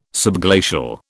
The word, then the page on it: subglacial: British English